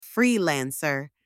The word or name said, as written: freelancer